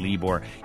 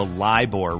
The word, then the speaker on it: LIBOR: You rock, says Anne, but you mispronounced the LIBOR rate. I was saying LIBOR. It's the LIBOR rate.